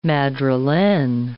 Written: madrilene

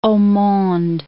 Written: haut monde

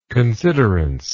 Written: considerance

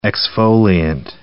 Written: exfoliant

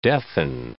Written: deafen

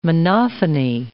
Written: monophony

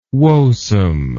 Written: woesome

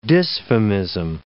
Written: dysphemism

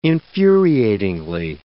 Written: infuriatingly

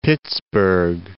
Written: Pittsburgh

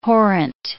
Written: horrent

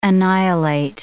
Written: annihilate

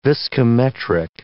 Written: viscometric

